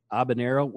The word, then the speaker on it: Habanero: Habanero with the H-A-B, but the H is silent. Like ABBA, like the band.